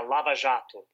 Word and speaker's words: Lava Jato: the Lava Jato. Is that the right way to pronounce it? – Yeah, Lava Jato.